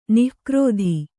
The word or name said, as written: ನಿಕೃತ